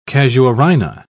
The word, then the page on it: casuarina: British English